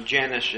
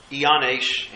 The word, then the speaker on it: Jannes: We pronounce them Jannes and Jambres, Iannes and Iambres.